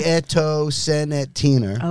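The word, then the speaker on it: Nieto Senetiner: No, and this is made by Nieto Senatiner. – Yes. – If I'm pronouncing that correctly. – I think you are. It's phonetic, isn't it? – Yeah. – Yeah. – Nieto Senatiner.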